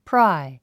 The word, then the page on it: pry: prái; プラァイ